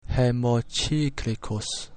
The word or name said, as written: hemocyclicus